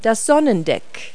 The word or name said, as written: sundeck